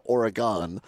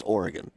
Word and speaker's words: Oregon: Problem is that the suburb of Madison, they pronounce it Oregon, and now the state is Oregon.